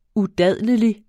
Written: udadlelig

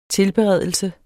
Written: TILBEREDELSER

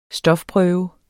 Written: stofprøve

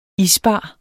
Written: isbar

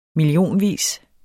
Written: millionvis

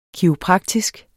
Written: kiropraktisk

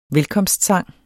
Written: velkomstsang

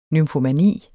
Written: nymfomani

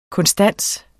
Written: konstans

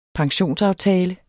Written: pensionsaftale

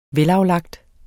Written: velaflagt

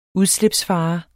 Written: udslipsfare

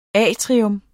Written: atrium

